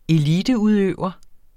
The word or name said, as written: eliteudøver